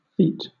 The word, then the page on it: feat: British English